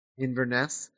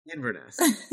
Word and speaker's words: Inverness: But he promises payment when they return to how do you say this? Inverness? – Inverness. – I was worried I was saying it wrong. – Inverness. It just sounds like you're saying Inverness. – Yeah. But it's Inverness.